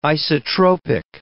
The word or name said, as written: isotropic